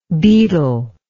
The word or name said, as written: beetler